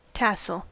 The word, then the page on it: tassel: American English